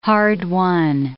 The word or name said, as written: hard-won